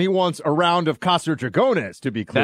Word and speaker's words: Casa Dragones: Casa Dragones. – Dragones. Right. I would basically pronounce it Casa Dragons, right? Dragones, but it sounds cool either way.